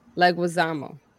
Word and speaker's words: Leguizamo: And Thursday, we have a special with Marie Leguizamo. – Leguizamo. – I'm sorry. I pronounce the G like we're Hispanic and shit. Like, you know. – So say it in Spanish. – Leguizamo.